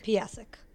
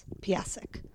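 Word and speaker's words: Piascik: Piasek. I try to do the S. Piasek.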